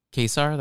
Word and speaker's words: Kaysar: um, uh, Kaysar. That's how you say his name.